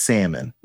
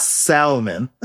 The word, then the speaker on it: salmon: It's pronounced salmon. It's not salmon. Scrimp is not a thing.